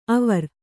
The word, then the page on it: ಅವರ್: Kannada